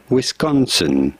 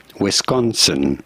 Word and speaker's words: Wisconsin: How do you go about pronouncing it? Wisconsin. You do want to stress on the second syllable, the con syllable. Wisconsin.